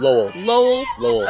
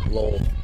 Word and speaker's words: Lowell: Now we have number 12, Hannah Lowell, or how did you say it last time? – Lowell. – Up to bat. – L-O-L, Lowell. Just like that.